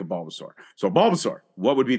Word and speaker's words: Bulbasaur: Bulbasaur. Thank you. – Like dinosaur. – Yeah, Bulbasaur. There you go.